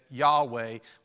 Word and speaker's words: Yahweh: So we're not sure, but scholars think that Yahweh was the original way to pronounce it.